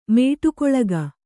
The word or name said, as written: ಮೇಟಿಗೊಳಗ